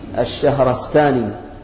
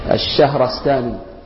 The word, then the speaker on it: الشهرستاني: وكذلك قال الشيخ أبو عبد الله محمد ابن عبد الكريم، سقطت التاء بعد السين، الشهرستاني بفتح الشين مشددة مفتوحة، الشهر مع فتح الراء، الشهرستاني، سقطت عندنا التاء هذه في هذه الطبعة، الشهرستاني هكذا نطقها وهكذا ضبطت.